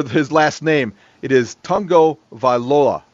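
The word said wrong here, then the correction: Tagovailoa: No, it's Tago Vailoa.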